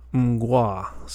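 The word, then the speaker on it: Mgła: You can click the little speaker and it'll, you know, somebody from Poland will say the word to you, and they pronounce it mgwa. So it's like an M sound and then a G-W-A-H sound. Gwa. Mgwa. So that's what I'm going with.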